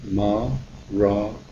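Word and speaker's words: Maranatha: If you choose that word, say it as four syllables, with equal stress on each syllable, Ma-ra-na-tha.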